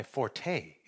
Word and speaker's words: forte: The proper pronunciation of forte is really fort. It's kind of like a fart with an O in it. Fort.